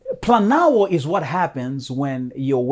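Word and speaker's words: planao (πλανάω): The specific Greek term used for deception, the verb here, is planao in Greek. It almost sounds like the word plan.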